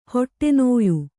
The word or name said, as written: ಹೊಟ್ಟೆನೋಯು